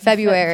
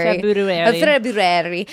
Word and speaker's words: February: I said it like you spell it, February. – February.